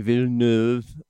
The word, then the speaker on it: Villeneuve: And that's the Denis Villeneuve opus. It's 2049, I'm sorry. – You don't pronounce the last nine letters in a French name.